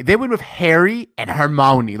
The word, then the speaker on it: Hermione: And the cool thing, I gotta say it, the pronunciation is Hermione.